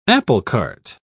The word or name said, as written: applecart